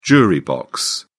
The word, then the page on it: jury box: British English